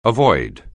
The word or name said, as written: avoid